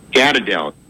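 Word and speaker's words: Gadreel: Gadadel. Okay, now, it's G-A-D-R-E-E-L, and it's also in Arabic, it's G-A-D-R-I-E-L, okay?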